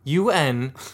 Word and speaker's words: Untappd: Well, since he's stalling, I'll spell it. UNTAPPD. Untappd.